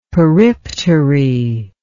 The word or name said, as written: periptery